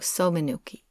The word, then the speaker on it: somenuki: And most of them, not all of them, but most of them are customized yukata called a samanuki or a somanuki. I'm actually not sure how to say that. Okay. – How do you spell it? – S-O-M-E-N-U-K-I. – Oh, somanuki. – Yeah.